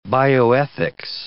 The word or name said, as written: bioethics